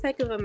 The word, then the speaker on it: Hecava: It's Hecava, Mecca.